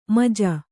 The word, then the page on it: ಮಜ: kannada